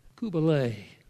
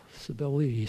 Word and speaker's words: Cybele: Kubalae, Cybele, Cybele, as we sometimes pronounce them.